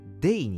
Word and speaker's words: Dea: えー、タイトルにもある通りですね、グローリア・デア。まあ、実はですね、発音はデイに近いらしいですね。